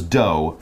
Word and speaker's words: dough: But basically, before you add the heat, the bread was dough first. And notice D O U G H, but it's just pronounced like D O, hmm.